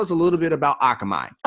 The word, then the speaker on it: Akamai: We have Simone Jones of Akamai LLC. Did I say that right? – No, it's Akamai.